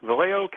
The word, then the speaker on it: Vallejo: What's out of line is I was just reading Vallejo. Am I pronouncing the name right? Vallejo, California.